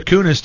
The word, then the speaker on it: Kunis: Kunis is another dirty sounding name. – But that's the right way to say it.